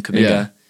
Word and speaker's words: Kuminga: That's how you say his name, Kuminga.